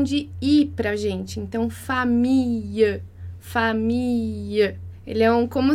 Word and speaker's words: famille: Então, a gente não fala famille, a gente fala família, família. Com o E fechado.